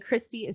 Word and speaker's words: Kristi: And Christy is spelled K-R-I-S-T-I. So Christy with a K and an I.